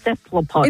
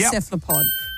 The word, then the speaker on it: cephalopod: Cephalopod. – It is a cephalopod. – That's how you say it.